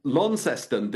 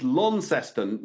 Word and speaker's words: Launceston: I mean, I was told when I was in Launceston that you've got to hit the, it's Launceston, not Launceston. Is that correct? – Lawn, yeah, got to say lawn.